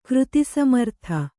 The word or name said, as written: ಕೃತಿಸಮರ್ಥ